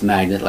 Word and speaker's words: magnate: So again, M-A-G-N-A-T-E, magnet. It's still pronounced magnet like the regular magnets attracts something together.